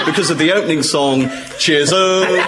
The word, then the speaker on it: Chisum: It stars John Wayne, and it's called Chisholm. Thank you. And it's spelt C-H-I-S-U-M.